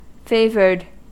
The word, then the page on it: favoured: American English